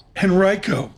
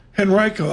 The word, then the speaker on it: Henrico: Henrico? – Yeah. Henrico. Yeah, there you go. – Henrico, I'll get that.